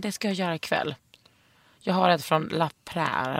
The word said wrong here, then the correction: La Prairie: eller hur det uttalas. – La Prairie. Ja, La mm. Prairie.